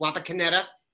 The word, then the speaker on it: Wapakoneta: Yesterday, Wapakoneta. I believe that was accurate. Not Patascala. It's Wapakoneta. Very interesting quarterback duel up there will be the headline.